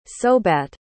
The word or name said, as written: sobat